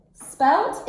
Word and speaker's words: spelt: Spelt, it sounds like, so in English we spell it with a T. – Right. – S-P-E, single L, T, spelt.